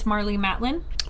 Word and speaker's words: Marlee Matlin: With, we were talking about how to pronounce the name. It's Marlee Matlin.